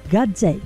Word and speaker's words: Gazzè: Max Gazzè, sotto casa. Auguri ancora di buon compleanno a Max Gazzè. Lui nacque a Roma nel 1967. Gazzè, G-A-Z-Z-E. Questo with an accent on the E.